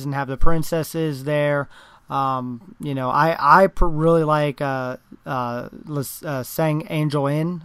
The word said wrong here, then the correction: San Angel Inn: I can never say that right. – San Angel Inn.